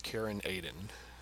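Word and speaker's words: Caern Adan: If you've read the book, that's how it's pronounced in the audiobook, Karen Aiden.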